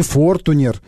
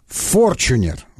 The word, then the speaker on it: Форчунер: Не Фортунер, не Фортунер, не Фортунер, а Форчунер. Вот так он произносит. Форчунер, да.